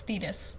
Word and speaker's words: Thetis: No, wait, because Thetis is the one that sounded Thetis. Like lettuce, so it's Thetis. Yes. – Okay, Thetis.